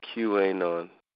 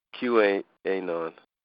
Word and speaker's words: QAnon: People pronounce it QAnon. QAnon.